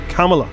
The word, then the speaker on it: Kamala: She's only new, and people have pronounced it. It's Kamala.